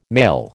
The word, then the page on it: male: American English